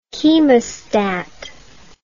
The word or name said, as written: chemostat